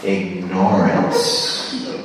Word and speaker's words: ignorance: He would say ignorance. He wouldn't stress the first syllable, ig. He would stress the second syllable, no. Because then you remember the word ignore.